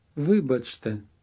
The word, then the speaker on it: Вибачте: Вибачте. – Notice where the stress is, on the syllable that sounds like Ви.